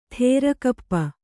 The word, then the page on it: ಥೇರಕಪ್ಪ: thērakappa